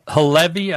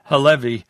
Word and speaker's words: Halevi: The IDF chief of staff, this Lieutenant Herzi Halevi, I think is the way he pronounced his name. I've heard it pronounced a couple of different ways in the press, but we'll call him Halevi, and he'll probably call if he doesn't like the way we're pronouncing his name.